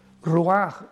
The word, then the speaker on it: ruach: Ruaj, R-U-A-C-H, la C-H en hebreo se pronuncia como una J, ruaj, que significa espíritus.